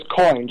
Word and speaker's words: Coyne: It's Coyne, just like Coyne. – Money. – Coyne, okay. – Like nickels and dimes.